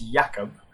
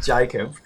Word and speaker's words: Jakub: Well, first of all, it's Jakub. Not Jacob. Nice Australian pronunciation.